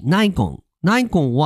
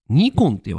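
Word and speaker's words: Nikon: ですとか、まあ、いろんな会社があって、あ、ちなみに、ナイコンは、日本では、なんとですね、ニコンって言われてます。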